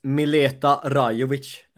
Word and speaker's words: Mileta Rajovic: So it's Mileta Rajovic. Right. It's a ch, not a k, in the end of Rajovic.